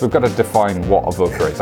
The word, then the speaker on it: Avoka: Yeah, you got the Avoka bit wrong. – Oh, I see. You knew I'd pronounce something wrong. Avoka, right. Yeah, it's Corcoran and Avoka.